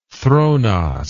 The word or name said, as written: thronos